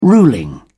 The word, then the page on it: ruling: British English